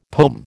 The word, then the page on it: pome: American English